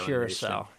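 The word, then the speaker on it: Curaçao: Messi got a goal in the 89th, as well as a 7 0 win over uh, Curaca. I didn't pronounce that right. Uh, small uh, oh, Curacao.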